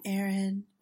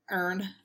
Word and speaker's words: Erin: We know that when my accent gets strong, it becomes, I don't know, not my name. It becomes like a earn.